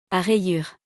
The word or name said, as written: A rayures